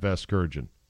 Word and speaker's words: Vasgersian: Vergasian, is that how you say it? – No, you're not saying it right. – They don't know how to say it. – And by the way, Francesa, being a self-proclaimed baseball guy, should know very well about Matt Vasgersian.